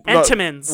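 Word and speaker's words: Entenmann's: Well, doesn't Enemans make them out of a box? – Enemans? – I'm from Long Island. You don't pronounce shit. – Enemans. They. – Entenmans.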